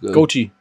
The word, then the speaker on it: Goatee: Goatee. – Goatee, ne? Ich weiß, wie es geschrieben wird, aber ich kann es mal nicht aussprechen. Goatee, ich habe Goate gesagt, Goate.